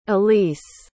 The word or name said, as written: aleece